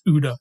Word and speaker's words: OODA: You're allowed to pronounce that. It's OODA. It's much more fun to say.